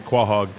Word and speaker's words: quahog: Is that how it's pronounced? I've, I've heard it quahog. quahog, quahog, quahog. My family always pronounced it quahog.